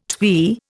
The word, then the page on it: twee: Indian